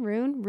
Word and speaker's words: Roon: And my family calls me Rune. Oh. R-O-O-N. Rune.